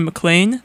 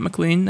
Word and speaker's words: McLean: So, Mom, hope you're doing well in Virginia right now, in McLean. Not McLean, that's not how you say it.